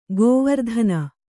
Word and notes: ಗೋವರ್ಧನ: gōvardhana; Kannada

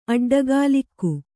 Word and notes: ಅಡ್ಡಗೋಲಿಕ್ಕು: aḍḍagōlikku

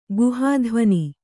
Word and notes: ಗುಹಾಧ್ವನಿ: guhādhvani

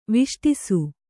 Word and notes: ವಿಷ್ಟಿಸು: viṣṭisu

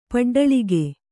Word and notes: ಪಡ್ಡಲಿಗೆ: paḍḍalige

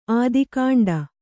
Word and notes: ಆದಿಕಾಂಡ: ādikāṇḍa

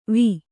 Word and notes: ವ: va

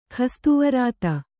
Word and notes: ಖಸ್ತುವರಾತ: Kannada

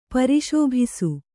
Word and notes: ಪರಿಶೋಭಿಸು: kannada

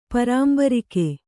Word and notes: ಪರಾಂಬರಿಕೆ: Kannada

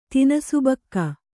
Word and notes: ತಿನಿಸುಬಕ್ಕ: kannada; tinisubakka

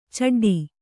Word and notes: ಛಡ್ಡೆ: chaḍḍe

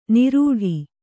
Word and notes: ನಿರೂಢಿ: nirūḍhi

